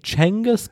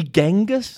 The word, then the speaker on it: Chinggis: That was my discovery this week, that we've been pronouncing Genghis wrong this whole time. It's Chinggis or Chinggis.